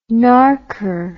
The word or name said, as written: narker